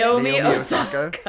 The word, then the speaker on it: Naomi Osaka: Naomi Osaka.